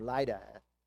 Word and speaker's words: Lydda: or Lydda, however you want to pronounce it, right, Dave? So he comes down to Lydda. I was pronouncing it Lydda, so I'll go with Dave's pronunciation.